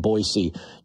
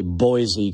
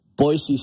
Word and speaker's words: Boise: That's right, I said Boise. Normally I'll say Boise because that's how we pronounce it here in Montana, but I'm going to pronounce it right. Boise State women.